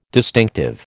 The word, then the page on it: distinctive: American English